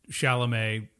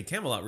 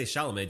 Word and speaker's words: Chalamet: our friend called him Timothy Camelot recently. Like, people who don't know how to say Chalamet just don't know how to say his last name.